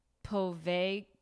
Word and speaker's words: Poveglia: I do take Italian. It would be Povelia. Oh, well, whatever. Don't tell Povelia.